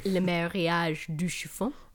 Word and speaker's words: Le Mariage du Chiffon: You want to pronounce that in French for me? – Le Mariage du Chiffon.